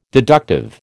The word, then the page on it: deductive: American English